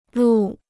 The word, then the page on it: 录: lù